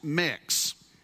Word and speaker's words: Mx: Others say that we need to replace Mr. and Mrs. with MX, which is pronounced mix.